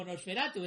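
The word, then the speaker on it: NOS4A2: hay que decir que Nosferatu no se escribe como Nosferatu, es Nos 4A2, o sea, Nos 4A2. O sea, que se pronuncia Nosferatu.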